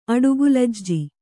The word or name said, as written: ಅಡುಗೂಲಜ್ಜಿ